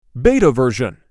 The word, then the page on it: BETA version: BETA